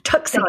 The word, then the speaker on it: Tucson: Tucson.